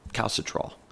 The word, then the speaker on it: calcitriol: It's called calcitriol in the kidneys, and I may have butchered that name, but it's spelled C-A-L-C-I-T-R-I-O-L.